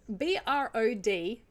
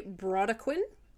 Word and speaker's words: Brodequin: The next is, it's spelled BRODEQUIN. I'm going to say Brodequin. Would you say that? – That sounds lovely. – We'll go with Brodequin.